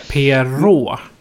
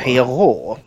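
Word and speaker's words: Pierrot: Eh, och då har vi en karaktär som kallas för Periot. Eller Peirot. Jag vet inte riktigt hur jag uttalar det fel eller inte. – Perot brukar det vara. Perot.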